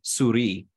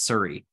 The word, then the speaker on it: Souris: It's it's near Surrey, uh, Surrey PEI, which is which is actually the French word souris, like for mouse or uh, S O U R I S, but I've been corrected many times that's pronounced Surrey in PEI.